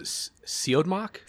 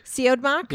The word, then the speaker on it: Siodmak: I pronounce it Siodmak. – Siodmak? – Yeah. – That's how I pronounce it too, but I was like, I don't know.